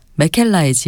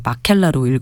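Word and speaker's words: Mäkelä: 역시 오슬로 필하모닉의 음악 감독을 맡고 있는 클라우스 맥켈라의 지휘로 준비했는데 이 맥켈라를 발음할 때 항상 저는 갈등이 되더라고요. 이거 마켈라로 읽어야 될까? 맥켈라로 읽어야 될까? 원래 이제 A와 A 글자에 위에 점점 이렇게 붙은 게 독일에서는 우물라우트라고 해서 에 발음이 맞는데 또 이 핀란드 그러니까 북유럽에서 이렇게 이 A에 이렇게 우물라우트가 붙은 거는 또 아에 가까운 에 발음이라고 하더라고요.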